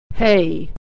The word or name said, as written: hay